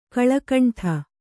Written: ಕಲುಗುಂಡು

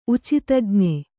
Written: ಉಚಿತಜ್ಞೆ

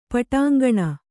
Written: ಪಟಾಂಗಣ

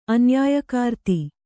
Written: ಅನ್ಯಾಯಕಾರ್ತಿ

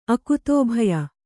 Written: ಅಕುತೋಭಯ

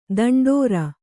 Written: ದಂಡೋರೆ

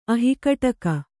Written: ಅಹಿಕಟಕ

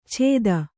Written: ಚೇತ

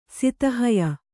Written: ಸಿತಹಯ

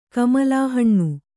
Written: ಕಮಲಾಹಣ್ಣು

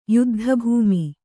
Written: ಯುದ್ಧಭೂಮಿ

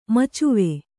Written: ಮಚ್ವೆ

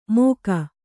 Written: ಮೋಘ

